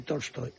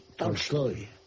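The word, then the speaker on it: Tolstoy (Толстой): No es exactamente Tolstoy, es Tolstoy.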